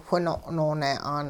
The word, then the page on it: whino' noone'aan: hupa